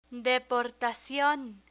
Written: deportacion

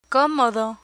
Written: comodo

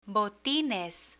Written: botines

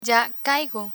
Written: ya caigo

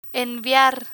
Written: enviar

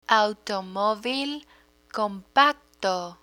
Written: automovil compacto

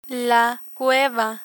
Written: cueva

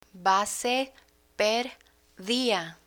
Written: base per dia

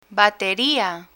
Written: bateria

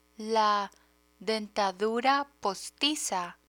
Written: dentadura postiza

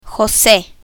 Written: jose

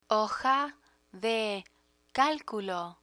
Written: hoja de calculo

